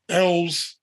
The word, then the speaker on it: Elwes: And the reason why is because, you know, you spoke on something, and I looked up the Cary Elwes, if that's how you pronounce it, who played Wesley. – Elwes. I actually looked up the pronunciation because I wasn't sure. – Elwes, okay.